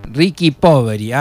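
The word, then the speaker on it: Ricchi e Poveri: Y nos dice: Mamma Maria, tante grazie, dice Ricky Poveri, así se pronuncia, Ricky Poveri.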